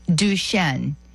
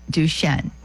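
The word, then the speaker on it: Duchesne: Duchenne, and that's, I do believe it's spelled D-U, I think it's C-H-E-N-E, Duchenne.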